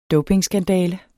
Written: dopingskandale

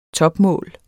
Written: topmål

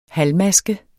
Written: halvmaske

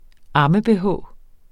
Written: amme-bh

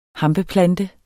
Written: hampeplante

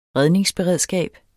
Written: redningsberedskab

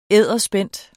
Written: edderspændt

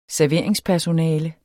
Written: serveringspersonale

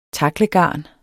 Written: taklegarn

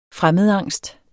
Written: fremmedangst

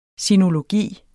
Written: sinologi

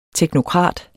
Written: teknokrat

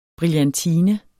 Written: brillantine

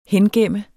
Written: hengemt

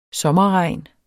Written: sommerregn

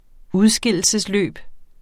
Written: udskillelsesløb

